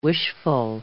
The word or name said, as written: wishful